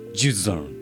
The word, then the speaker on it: Jusant: 正式な発音で言うと「ジュザン」って言うらしいです。あなるほど T は最後発音しないのがフランスっぽいね。